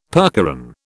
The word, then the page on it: percheron: British English